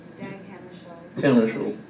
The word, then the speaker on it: Dag Hammarskjöld: Dag Hammarskjöld.